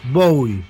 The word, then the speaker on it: Bowie: «Ποια είναι η σωστή προφορά του ονόματός του?» «Bowie ή Bowie?» «Bowie», το έχει πει και ο ίδιος.